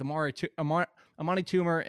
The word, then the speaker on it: Amani Toomer: Amani Toomer and Ike Hilliard was which you can't say his name like that. It's Armani. It's not a tumor, is how you pronounce his name.